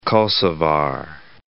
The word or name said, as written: Kosovar